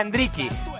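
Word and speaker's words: Endrick: Y os digo que Hendrick en Brasil se pronuncia Hendricky,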